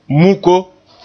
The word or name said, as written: muko